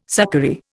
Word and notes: succory: Indian

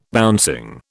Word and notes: bouncing: British English

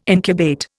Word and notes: incubate: Indian

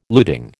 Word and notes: luting: American English

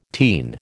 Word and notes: teen: American English

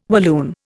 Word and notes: Walloon: Indian

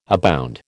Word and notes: abound: American English

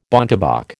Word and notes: bontebok: American English

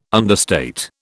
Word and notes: understate: British English

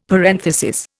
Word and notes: parenthesis: Indian

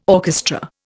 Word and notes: orchestra: Indian

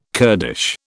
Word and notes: Kurdish: British English

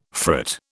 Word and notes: frit: British English

